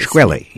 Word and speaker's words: Shkreli: You, in your first chapter of the book, talk about Martin Shkreli. I'm not sure if I'm pronouncing it. – Shkreli, yes.